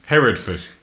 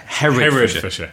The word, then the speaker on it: Herefordshire: Herefordshere? – Herefordshire. – Herefordshire in England. It's very near Wales. There's a long... Yeah, how do you say... How do you say Herefordshire? – Herefordshire.